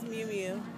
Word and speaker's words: Miu Miu: My first name is Miu Miu. – Miu Miu. How do you spell that? – M-I-U-M-I-U.